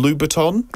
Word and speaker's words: Louboutin: The bride chose, how do I say this, Christian Louboutin? – That's correct.